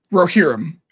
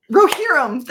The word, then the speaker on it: Rohirrim: Rohirrim. – Rohirrim.